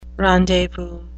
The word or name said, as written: rendezvous